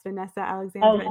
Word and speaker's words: Vanessa Alexandre: So to give her props, Vanessa Alexander. – Oh, yeah. Did I say her name right? – I think you did.